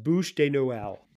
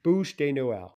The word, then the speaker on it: Bûche de Noël: It's pronounced Bûche de Noël. Bûche de Noël. – Bûche de Noël. Okay, so I had the bûche done. – Yeah, you had the bûche part right.